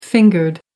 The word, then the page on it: fingered: American English